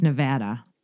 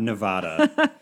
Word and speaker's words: Nevada: First off, Mark, it's Nevada. – Not Nevada. That is a pet peeve of longtime residents.